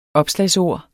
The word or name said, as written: opslagsord